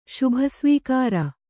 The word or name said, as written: ಶುಭಸ್ವೀಕಾರ